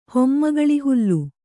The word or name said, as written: ಹೊಮ್ಮಗಳಿಹುಲ್ಲು